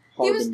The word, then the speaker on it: Harbinger: He was the Harbinger. Is that how you pronounce that? Doesn't matter. – Harbinger. – He was Harbinger. Thank you.